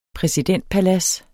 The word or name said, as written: præsidentpalads